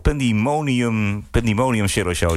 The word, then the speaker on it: Pandemonium Shadow Show: Pandemonium Shadow Show.